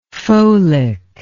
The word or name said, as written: folic